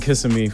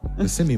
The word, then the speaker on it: Kissimmee: It's not Kissimmee. It's Kissimmee. I've been there. – Wait, he's either in Kissimmee, Florida, or Kissimmee. – It's Kissimmee. – Yeah. – But change it. – Kissimmee, my ass.